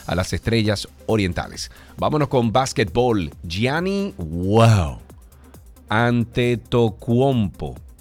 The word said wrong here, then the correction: Antetokounmpo: ¿Lo dije bien? Ok, bueno, Antetokounmpo, así mismo.